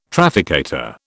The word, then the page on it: trafficator: British English